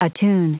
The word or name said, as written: attune